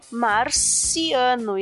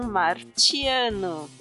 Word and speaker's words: marciano: Então, você pronunciar letras T como letra C, como na palavra international, por exemplo, tá? ou no nome Alicia, que é pronunciado Alicia. Né? Então, a mesma coisa acontece aqui com Marte tá? Essa palavrinha latina que tinha essa pronúncia martius, de marcial, né? Ou Mars, como no inglês ficou o nome do planeta, Mars. Né? Então, marciano e não martiano.